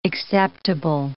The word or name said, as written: acceptable